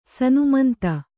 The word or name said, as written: ಸನ್ಮಂತ